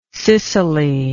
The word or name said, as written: cicely